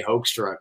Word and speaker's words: Hoekstra: So I went, okay, I guess we're just, you know, look, my name is supposed to be, if you do the Dutch pronunciation, have like a hook. – Hoekstra. – You know, like a hook, but nobody would ever get that out of H-O-E-K.